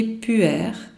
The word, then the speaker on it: pu-erh: Je répète, le thé qui favorise une bonne flore est le thé puer, ça s'écrit P-U-E-R-H, que vous pouvez trouver chez Sequoia.